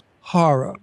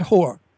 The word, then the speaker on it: horror: Well, that's pronounced horror, by the horror. – Way. – Horror. – Not whore.